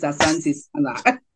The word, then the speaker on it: Asante Sana: Okay, I was going to say the only one I know is Asante Sana, but I wasn't sure what it meant. – Yes, you said it right.